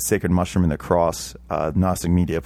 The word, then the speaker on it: Gnostic Media: So if you have the same level of intelligence as me and you think it's spelled with an N first, you're incorrect. It's Gnostic space, then media, M-E-D-I-A.